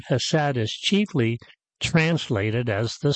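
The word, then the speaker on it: chesed (חסד): That day, she introduced me to the Hebrew word chesed, H-E-S-E-D, chesed. Sometimes it's spelled chesed with a C in front of it.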